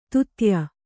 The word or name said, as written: ದೂತ್ಯ